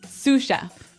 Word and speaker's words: sous: That brings us to our second word, sous chef. – Sous chef. – Sous chef. – Sous chef. – S-O-U-S. – So the S is silent because mm-hmm. it's like a French word.